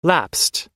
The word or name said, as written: lapsed